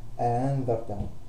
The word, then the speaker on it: أأنذرتهم: فأنا هنا عندي أو الفتح بين الألف والهمزة المحققة، فعندي آ ما انطقهاش هاء، ما أقولش أها ولا أقول أآ، إنما بين الاثنين آها أأنذرتهم. طيب، فله في الهمزتين من كلمة تسهيل الهمزة الثانية، يحقق الأولى ويسهل الثانية، ويدخل بينهما ألف فيقول أأنذرتهم أأنذرتهم.